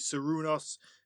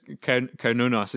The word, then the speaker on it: Cernunnos: I know I'm going to butcher the name, but is it Serunos? Is the one that you had kind of told me about? You go. I can see you smiling, so you pronounce the name properly. – No, just Cernunnos.